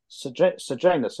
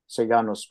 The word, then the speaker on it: Sejanus: And in the same way that Augustus trusted Agrippa, Tiberius trusted Sejanus, if I use the English pronunciation. Sejanus probably is more correct in Latin.